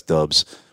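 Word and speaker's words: Doubs: And Romeo Dubs, I wish it was Dubs, D-O-U-B-S. I guess that's Dubs.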